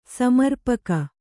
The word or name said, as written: ಸಮರ್ಪಕ